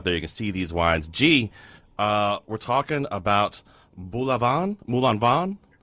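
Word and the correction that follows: Moulin-à-Vent: How do I say that? – Not Moulin Avant. – Moulin Avant. – Moulin Avant. Yes, Moulin Avant.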